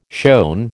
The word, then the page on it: shown: American English